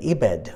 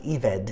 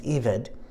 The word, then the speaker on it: ebed: They are an ebed, or it's actually pronounced eved. It looks like E-B-E-D, but it's pronounced eved.